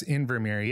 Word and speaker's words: Invermere: Did I say that right? Invermere? – Yes, Invermere. Yeah. So where is that in relation to, say, Vancouver or something that people maybe can find on a map? – Yeah.